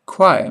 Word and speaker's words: choir: Choir must always rhyme with wire.